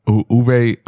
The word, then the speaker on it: Uwe: How do you pronounce his name? – Uwe. – Okay. Yeah. Uwe.